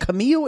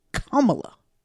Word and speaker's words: Kamala: Because you said Camille and you said Camilla and you said something other. And her name is Kamala. I was like, dang. That's how I figured out it was the right way to say it.